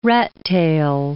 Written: rattail

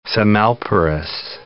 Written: semelparous

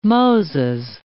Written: Moses

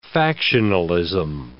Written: factionalism

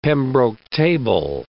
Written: Pembroke table

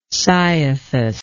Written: cyathus

